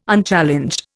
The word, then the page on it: Unchallenged: Indian